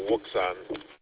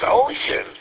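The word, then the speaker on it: Wouxun: Those radios have been around for several years now, and all I've ever heard them called is a Wuxon. – Exactly, me too. But actually, if you look it up, it says they're pronounced ocean.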